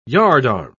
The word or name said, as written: yardarm